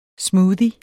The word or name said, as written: smoothie